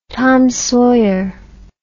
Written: Tom Sawyer

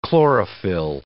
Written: chlorophyll

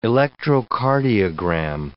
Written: electrocardiogram

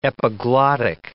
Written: epiglottic

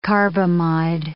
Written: carbamide

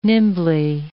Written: nimbly

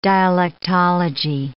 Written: dialectology

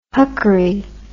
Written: huckery

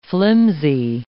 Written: flimsy

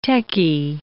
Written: techie

